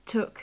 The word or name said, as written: took